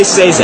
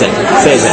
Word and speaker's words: Sazed: One of my favorite characters is Sayaz. Is that how you... – I say Sazed. Sazed.